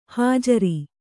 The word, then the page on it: ಹುಜರು: hujaru